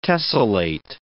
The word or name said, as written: tessellate